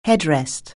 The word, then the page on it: headrest: anglais